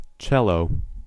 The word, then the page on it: cello: American English